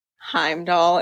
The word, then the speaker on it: Heimdall: It, within the context that you're presenting, like the what you've just said about Heimdall, am I saying his name right? – Yeah.